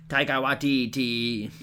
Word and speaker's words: Taika Waititi: Then you've got, I really hope I'm pronouncing this right, but Taika Waititi, I believe his name is? – Taika Waititi.